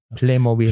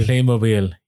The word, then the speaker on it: Playmobil: How is somebody called in and actually told us how to pronounce it? Playmobil. – Playmobil.